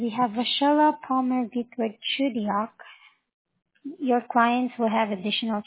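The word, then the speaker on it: Chugiak: I'm sorry, that's Chudiar? – Oh, Chudiak, I spelled it. – Can you please spell it for me, please? – Yeah, C-H-U-G-I-A, and then K.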